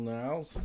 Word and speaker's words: Niles: Uh, warm welcome to Janelle Niles. Is this how you say your last name, right? Yep. Niles. – Niles like Frasier.